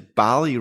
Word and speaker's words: Balli: but it is BALLI, right? – It is BALLI. BALLI like Cali.